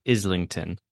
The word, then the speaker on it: Islington: Well, I have to just say it's Islington is pronounced Islington.